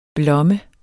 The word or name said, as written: blomme